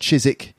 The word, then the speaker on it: Chiswick: Chiswick, it should be pronounced Chiswick.